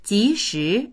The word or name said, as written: ji2shi2